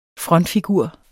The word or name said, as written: frontfigur